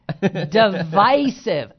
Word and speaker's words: divisive: The standard pronunciation is with a long I. – Uh-oh. – Hello. – Hey, but I say milk, so, you know, I say a lot of words wrong. – Divisive.